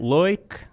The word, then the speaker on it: Leuck: I'm Matt Leuck.